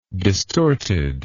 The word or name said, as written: distorted